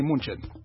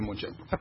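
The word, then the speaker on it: München: Haya ni Munchen. Bayern Munchen.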